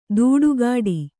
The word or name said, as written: ದೂಡುಗಾಡಿ